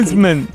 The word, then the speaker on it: msemen: Es una palabra complicada, decías M-S-E-M-E-N. Msemen, msemen, ¿vale? Y se pronuncia como lo ha hecho.